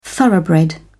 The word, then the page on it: thoroughbred: British English